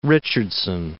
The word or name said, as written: Richardson